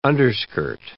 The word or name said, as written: underskirt